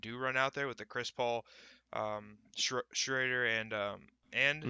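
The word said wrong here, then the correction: Schröder: I, I need to stop saying his name wrong. I, I just it freaks me out every time I say it. But SGA that, or Schroeder? – Schroeder, yeah, that guy.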